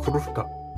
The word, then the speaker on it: krówka: では発音です。クルフカ。ルがちょっと巻き舌な感じですかね。